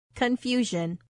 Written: confusion

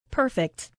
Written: perfect

